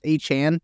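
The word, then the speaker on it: Achane: I think I think uh, his pronunciation guide now says Achan um, I I was always saying a chain. That's what I had always. But I was saying too. I thought it was a chain, but it's a Chan.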